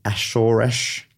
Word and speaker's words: Azores: The species is found off the coasts of Europe, Africa, the Ashoresh. I had to look up the pronunciation. I'm sure that's all right. It looks like it says the Azores, but the pronunciation I looked up said Ashoresh.